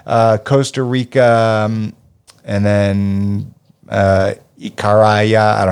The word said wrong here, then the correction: Icaria: No, I just didn't know how to pronounce the no, I- Icaria.